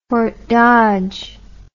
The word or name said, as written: Fort Dodge